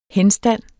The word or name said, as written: henstand